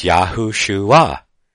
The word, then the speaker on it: Yahushua: Of course, it has the Greek, and then it has a pronunciation, and then he has a phonetic pronunciation of the name, and it says Yahushua. It's spelled Y-E-H, and he has dash H-O dash S-H-O-O to get the shu and the ah at the end spelled A-H. So it's Yahushua, and that is the phonetic pronunciation of the name of what we call in the West Jesus.